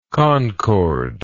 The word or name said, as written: concorde